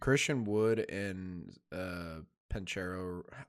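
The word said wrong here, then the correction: Banchero: How do you say his last name? – Bankero. – It's a B. Bankero.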